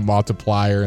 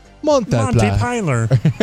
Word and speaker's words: Montpelier: And people said, y'all are killing me as y'all are pronouncing it multiplier instead of however you actually...